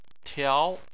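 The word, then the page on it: tiao: tone 2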